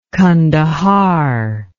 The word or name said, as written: kandahar